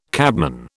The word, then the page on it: cabman: British English